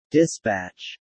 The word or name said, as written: dispatch